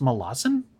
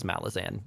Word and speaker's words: Malazan: Wait, it's supposed to be pronounced Malazan? – That's how the author wants it pronounced, but he is also given up, and he's just dealt with the fact that everyone says Malazan.